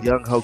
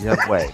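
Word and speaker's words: Young-hoe: Young-ho Koo, which apparently is pronounced Young-way.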